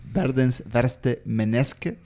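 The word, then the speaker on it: Verdens verste menneske: Por lo que leí, Trier explica que, mm, la frase eh, en, noruego, que si quieren me animo a leerla, es Verdens verste meneske.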